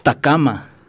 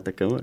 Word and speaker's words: tacama: Entonces, por favor, tenga la bondad de anotar y les voy a decir cómo se dice bizcocho en quichua. Es tacama. – Ah, tacama.